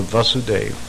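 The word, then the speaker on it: Vāsudeva: It's called the Vasudeva stage. Which is the same platform as Krishna, Vasudeva, long A.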